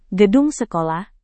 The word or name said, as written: gedung sekolah